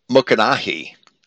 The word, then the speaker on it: Mycenae: Because Mycenae, or as I would pronounce it in ancient Greek, Mukanahi, Mycenae was the city of Agamemnon, who was the leader of the Greeks at Troy.